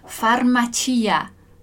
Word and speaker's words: farmacia: Farmacia. Farmacia. É importante prestar atenção aí no, onde que tá a sílaba forte ali, né, no italiano. Então não é nem farmácia e nem farmácia, é claro. Farmacia.